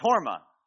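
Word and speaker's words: Hormah: It rhymes with Morma. Yeah, it's Horma. And so this is the same place.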